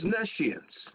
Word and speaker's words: nescience: And I'm going to tell you why this word is being ignored in 1 second, but the word is N-E-S-C-I-E-N-C-E. It looks like knee science, but it's pronounced nescience.